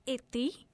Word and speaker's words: Iti: It's Ithi. It's pronounced, okay, it's spelled as I-T-I, but it is pronounced as I-T-H-I. So it's Ithi.